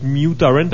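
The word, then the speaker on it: mutorrent (µTorrent): En fait, ça se prononce mutorrent parce que c'est comme le petit U en mathématiques, quelque chose.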